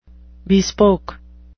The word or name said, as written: bespoke